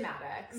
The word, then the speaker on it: Madix: Tom Sandoval has cheated on Ariana Madix. I think that's how you pronounce her surname. – Yeah, I would say Madix.